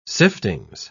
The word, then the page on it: siftings: British English